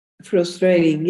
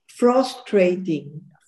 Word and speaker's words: frustrating: Frustrating, yes. – Mm-hmm. – Not frustrating. Frustrating. Okay. – What is that correct pronunciation? – The U is like up.